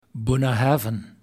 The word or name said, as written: Bunnahabhain